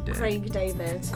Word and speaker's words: Craig David: Correggi la mia pronuncia. – Craig David. – Craig David. David. No? Craig David. Prova a dirlo tu.